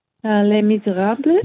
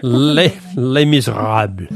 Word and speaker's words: Les Misérables: Les Misérables. – Yes, your pronunciation is a lot better than mine. Les Misérables.